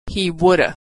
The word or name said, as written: he woulda